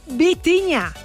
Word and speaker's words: Vitinha: Seguimos con Vitinha. No Bocinha, Vitinha.